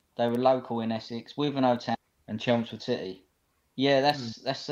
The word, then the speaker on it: Wivenhoe: Wivenhoe Town.